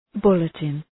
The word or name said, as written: bulletin